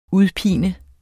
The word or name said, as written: UDPINER